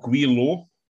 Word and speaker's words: Gwei: I'm drinking one called Guilo. It's spelled G-W-E-I. Guilo.